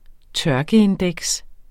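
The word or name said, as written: tørkeindeks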